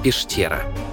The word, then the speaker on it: пещера: «Поща» – на русском это «почта», а произносится как «пошта», а «пещера» – как «пештера».